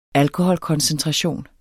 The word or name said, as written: ALKOHOLKONCENTRATION